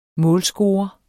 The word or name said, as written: målscore